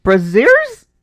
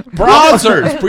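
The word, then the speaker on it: Brazzers: Brazers. Repeat after me. Mira. Braziers. – Braziers. – Brazers. – Brazers. – That's how they say it the second way. Brazers.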